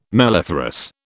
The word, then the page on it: melliferous: British English